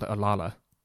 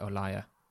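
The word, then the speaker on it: Olalla: By the way, I always thought it was called Olala, but I... – Is that how you pronounce it? – I discover that you're actually supposed to pronounce it Olaya.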